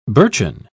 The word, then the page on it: birchen: British English